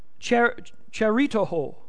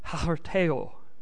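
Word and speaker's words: harteo (χαριτόω): Now, it looks like in English, if we try to pronounce it in English, it looks like charitoho. But I'm not even going to try to say it as the Hebrew word. Or the Greek word. If you look on, there's a program on the computer called Blue Letter Bible, and you can go in there and you can get the pronunciation. And you know how some of these words are at the beginning? That's what you got to do. It's harteo. That's how they pronounce it.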